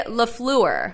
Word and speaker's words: LaFleur: Now I'm adding more syllables to LaFleur's name after Tom Pelissero did his whole thing on NFL Now about how it's three syllables.